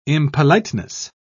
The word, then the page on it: impoliteness: British English